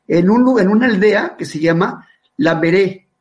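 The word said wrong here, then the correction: Lambaréné: Lamberene, Lamberene con doble, con doble acento en, eh, las voces.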